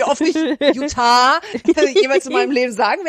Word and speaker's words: Utah: Und man lernt, wie man, das ist nicht, wie die Deutsche sagt, Utah, sondern Utah. Also ich kann es gar nicht Ah.